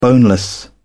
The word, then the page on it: boneless: British English